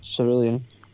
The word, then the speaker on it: Cerulean: Celadon City, Suri- Cerulean City, Cerulean.